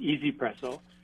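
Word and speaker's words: 1Zpresso: It's called, it's spelled onesie presso, but it's pronounced easy presso.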